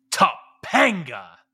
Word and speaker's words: Topanga: You didn't say it right. Topanga. You got to have that emphasis.